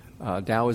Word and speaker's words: Tao: Tao is actually, we spell it with a T, T-A-O, but it's actually a soft D, Taoism.